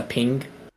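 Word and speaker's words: Peng: I'm assuming it's just pronounced a ping. It's Chinese. It's P-E-N-G.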